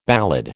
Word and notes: ballad: American English

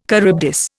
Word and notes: Charybdis: Indian

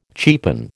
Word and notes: cheapen: American English

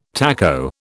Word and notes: taco: British English

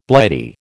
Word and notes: Blighty: American English

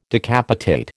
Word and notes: decapitate: American English

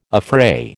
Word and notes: affray: American English

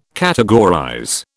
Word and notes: categorize: British English

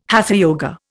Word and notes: hatha yoga: Indian

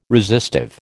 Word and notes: resistive: American English

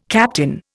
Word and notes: captain: Indian

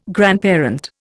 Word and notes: grandparent: Indian

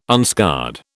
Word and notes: unscarred: British English

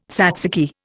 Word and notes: tzatziki: Indian

